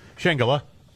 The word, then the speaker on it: Shangela: RuPaul's Drag Race star, is it Shangela? – Shangela. – Is that how you say it? Yeah.